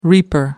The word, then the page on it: reaper: British English